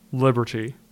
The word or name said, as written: Liberty